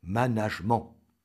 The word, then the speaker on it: management: mais qu'il est à prononcer à la française. Question, pourquoi singer alors l'accent anglais? Donc, retenez, chers auditeurs, management.